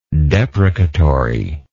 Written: deprecatoriness

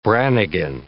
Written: Brannigan

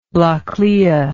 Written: Baile Atha Cliath

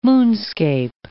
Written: moonscape

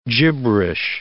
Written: gibberish

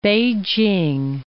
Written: Beijing

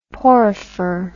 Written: porifer

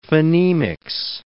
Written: phonemics

